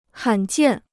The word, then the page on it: 罕见: hǎn jiàn